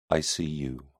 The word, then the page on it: icu: British English